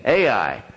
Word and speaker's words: Ai: The next city is Ai, one of the, you know, shortest city names ever. Ai. And that's how you say it. Ai.